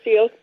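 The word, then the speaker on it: Syilx: You actually pronounced it very, very close to how it is said. We're Silk people and our language is in Silkchen.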